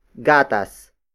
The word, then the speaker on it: gatas: And that is gatas. Gatas. Gatas. Gatas is spelled as G-A-T-A-S.